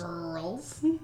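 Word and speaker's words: Grrrls: It's G-R-R-R-L-S. It sounds like this. – Girls.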